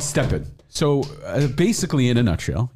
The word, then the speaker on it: STEPN: It's called Stepn. S-T-E-P-N. Stepn. Okay. – Spell it again. – S-T-E-P-N.